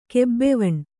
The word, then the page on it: ಗಬ್ಬವನೆ: kannada